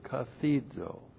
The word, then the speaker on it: kathizo: All right, first of all, we have the verb kathizo. Looks like that in the Greek, K-A-T-H-I-Z, long O.